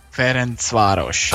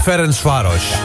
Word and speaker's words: Ferencváros: En dat spreek je dan weer zo uit. – Ferencváros. – Ah ja. Ferencváros.